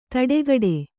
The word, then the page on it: ತಡೆಗಡೆ: taḍegaḍe